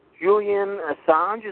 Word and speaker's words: Julian Assange: Yeah, apparently, Julian Assange, is that how you pronounce his name? – Yeah.